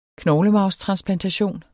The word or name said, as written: knoglemarvstransplantation